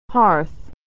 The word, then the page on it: hearth: American English